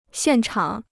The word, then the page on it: 现场: xiàn chǎng